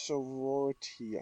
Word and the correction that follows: Sonority: I think is how you say it. – Sonority. – Sonority. – Oh, wow, Sonority, yeah.